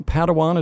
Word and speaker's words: Padawan: Padawan, is that how you say it? – That is how you say it.